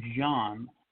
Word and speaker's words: Zhon: Pronounced Zhon.